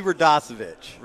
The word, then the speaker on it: Radosevich: Eddie Radosevich.